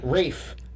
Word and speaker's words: Ralph: Rafe. It's Rafe. Did you know that? It's not Rafe. – Ray? – Rafe. It's like Ray. Ralph, but pronounced Rafe. – Yeah. – I didn't... – French pronunciation.